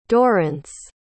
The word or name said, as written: dorrance